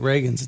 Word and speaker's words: Ragains: Do you go, because you spell it R-A-G-A-I-N-S, but you pronounce it like Ronald Reagan? – Yeah, I still get that all the time.